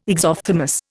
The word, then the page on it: exophthalmos: Indian